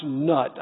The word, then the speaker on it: Nuit: You see, the Egyptian sky goddess, called Nuit, N-U-I-T, many times pronounced nut.